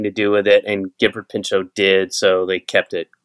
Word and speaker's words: Gifford Pinchot: The Gifford Pinchot National Forest is where your character is hiking in The Dark Divide, correct? Am I pronouncing it correctly? – Yes, that is correct.